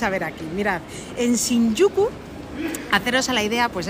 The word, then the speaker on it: Shinjuku: el barrio de Shinjuku. – Muy bien pronunciado. – Shinjuku, sí señor.